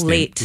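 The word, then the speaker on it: Leite: Carla Light, I hope I'm pronouncing her last late. – Name. Late. – Like the late show.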